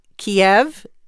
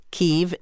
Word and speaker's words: Kyiv: And just a little note for our listeners who might be confused about Kiev Kiev is the Russian pronunciation of that city. Kyiv is the Ukrainian pronunciation, and the Ukrainians are very proud of their own language and want that city pronounced that way, and that's why diplomats do that.